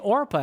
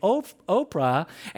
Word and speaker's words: Orpah: In fact, Oprah was named after this Bible character that we're mentioning right now out of Ruth, but people were always mispronouncing Orpah, and instead of saying Orpah, they were saying Oprah, and so as a result of that, Oprah stuck.